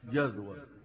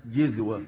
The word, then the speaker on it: جذوة: وقلنا آتيكم منها بخبر كما وضحت سورة طه أنه كان يريد من يخبره ويهديه ويدله على الطريق الصحيح أو جذوة، وقلت لكم هي تنطق في لغة العرب بالفتح جذوة وبالكسر جذوة وبالضم جزوة، وأشهر الروايات وأشهر القراءات قراءة الفتح التي وردت في القرآن الكريم أو جذوة.